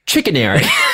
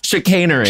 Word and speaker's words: chicanery: Chickenary. Chickenary. And then all of us burst out laughing. Laughed at this fucking woman. – Wait, I already forgot how you really say it. – Chicanery.